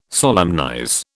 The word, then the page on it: solemnize: British English